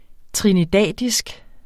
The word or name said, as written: trinidadisk